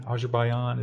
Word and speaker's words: Azerbaijan: Azerbaijan is how you pronounce that. Azerbaijani. Yeah, there you go.